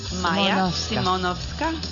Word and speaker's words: マヤ・シモノフスカ: Can you pronounce the name? マヤ・シモロブスカ、マヤ・シモノフスカと申します。